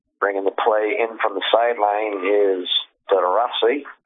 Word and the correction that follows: DeRozier: Did I say that right? – I think it's DeRozier. – DeRozier, yeah.